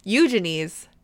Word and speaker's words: Eugenie: eugenie's uh wedding tiara like, Great pronunciation.